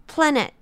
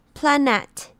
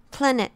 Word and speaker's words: planet: 是 planet，要注意 net 念 i 的音，不是 a，不要记住，不要念成 planet，而是 planet。